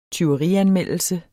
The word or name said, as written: tyverianmeldelse